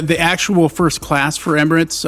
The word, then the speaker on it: Emirates: But uh, when you do, maybe you'll fly Emirates. Is that how you say it? – Emirates. Emirates. – Emirates.